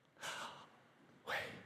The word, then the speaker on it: Yahweh: That's how they believe the name of God is pronounced. Yahweh. Yahweh.